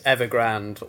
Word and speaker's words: Evergrande: The word of the year that I've chosen is Evergrande, or as some people will pronounce it when they're coming to the story for the first time, Evergrande. not Evergrande, Evergrande.